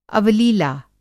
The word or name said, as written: अवलीला